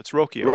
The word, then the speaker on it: Rocchio: Well, before I came to spring training and before that began, it probably would have been Roccaccio. I hope I'm saying that right. Roccaccio, who played at Double A. – Brian, is it Rocchio? It's Rocchio, right? – Rocchio. I'm sorry. Yeah, Rocchio.